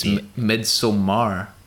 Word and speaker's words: Midsommar: I think most people just say Midsommar. – Midsommar. – Yeah. – But it's with an A. It's Midsommar. – Yeah, you could say Midsommar if you want.